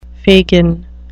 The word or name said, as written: fagin